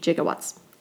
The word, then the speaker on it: gigawatts: They were pronouncing what we would say perhaps gigawatts with a soft G, gigawatts.